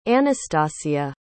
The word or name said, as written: Anastassia